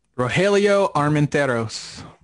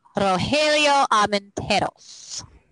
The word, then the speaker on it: Rogelio Armenteros: Rogelio Armenteros. – Thank you. Rogelio Armenteros.